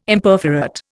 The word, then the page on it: Imperforate: Indian